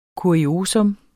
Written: KURIOSA

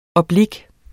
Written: oblik

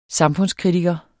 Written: samfundskritiker

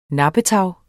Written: nappetag

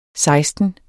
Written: seksten

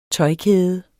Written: tøjkæde

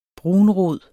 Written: brunrod

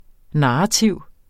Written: narrativ

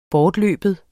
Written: bortløben

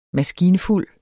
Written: maskinfuld

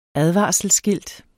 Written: advarselsskilt

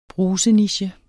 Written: bruseniche

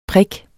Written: prik

